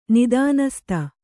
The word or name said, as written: ನಿದಾನಸ್ತ